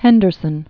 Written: Henderson

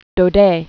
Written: Alphonse Daudet

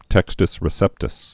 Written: textus receptus

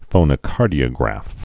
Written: phonocardiographic

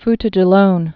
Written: Futa Jallon

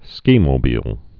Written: skimobile

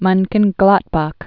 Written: Monchengladbach

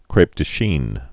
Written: crepe de Chines

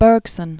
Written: Bergson

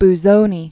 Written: Ferruccio Benvenuto Busoni

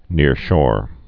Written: nearshore

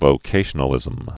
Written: vocationalism

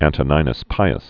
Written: Antoninus Pius

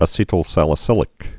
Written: acetylsalicylic acid

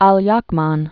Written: Aliakmon

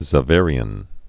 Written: Xaverian Brother

